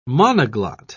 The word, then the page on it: monoglot: British English